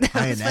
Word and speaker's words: Ionescu: Like yesterday with Sabrina Ionescu or whatever. Did I pronounce that right? – I got that right. – Wow, phenomenal, that was good. – Ionescu. Phenomenal.